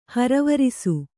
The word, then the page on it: ಹರವರಿಸು: haravarisu